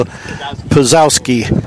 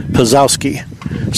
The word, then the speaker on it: Pszulkoski: Well, I got the phonetic version, and it isn't what it says on the paper. So Pazowski is how it is, and it is spelled P-S-Z-U-L-K-O-S-K-I, Pazowski.